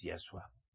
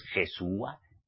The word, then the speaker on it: Yahshua: Su nombre auténtico es Yahshua. No es Jesúa ni Yeshua, como los cristianos mesiánicos lo pronunciáis.